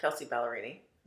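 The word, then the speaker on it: Kelsea Ballerini: I think it's Kelsey Ballerini. Am I saying it right? – Yeah. Kelsey Ballerini.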